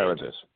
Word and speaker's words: Emeritus: So let me ask you this, because one of the things I noticed from your website was that you are, make sure I pronounce this right, is it Emeritus Senior Scientist? – Emeritus. – What does that mean exactly? Emeritus, there you go, thanks.